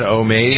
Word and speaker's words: Omaze: Is that how you pronounce that? Omaze? – Yep. Mm-hmm.